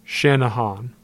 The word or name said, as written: Channahon